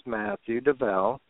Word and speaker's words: Matthew Dovel: My name is Matthew Dovel. And you did say it correctly. I'm very impressed by that.